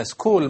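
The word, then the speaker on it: school: سكول مثلا، كلمة سكول اس بعدين سي اتش ما تنطق أيضا الاتش، وهكذا.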